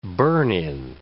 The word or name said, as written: burn-in